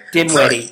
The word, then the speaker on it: Dinwiddie: Dinwiddie? Is that how you pronounce it? – Dinwiddie.